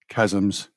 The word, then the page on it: chasms: British English